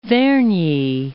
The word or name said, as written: Vernyi